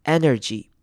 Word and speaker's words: energy: 好， energy 这个字要注意的是前面的 e 要记得念成短母音 e 的声音，不要念成 E 或者是 I 的声音，要念成 energy。